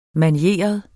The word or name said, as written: manieret